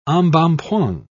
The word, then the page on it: embonpoint: British English